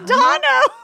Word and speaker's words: Dano: I've been mispronouncing it the whole time, but Paul Dano collects teeth. – Nuh-uh. – Yes. Wait. – No. – Wow. It's Dono? – It's Dono.